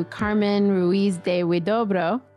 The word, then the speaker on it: Carmen Ruiz de Huidobro: We get to listen to Carmen Ruiz de Huidobro.